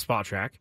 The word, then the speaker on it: Spotrac: I actually had a guest on one time that told me the correct pronunciation is Spotrac.